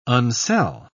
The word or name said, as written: unsell